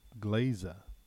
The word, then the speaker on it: Glaiza: So, another podcast I want to tell you about is called Classical Glaza, and I hope I'm pronouncing it right. I'm sure I am. Glaza is G-L-A-I-Z-A.